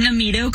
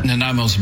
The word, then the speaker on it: Nanaimo: But it is Nanaimo, a wonderful historic indigenous name.